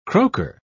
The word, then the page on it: croaker: British English